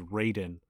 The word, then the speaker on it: Raiden: I should also say Raiden you were actually correct in his name pronunciation, which is Raiden.